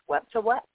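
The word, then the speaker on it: Wepwawet: And I also saw that, like, Anubis and the wolf-headed, I think it's pronounced Wet-to-Wet,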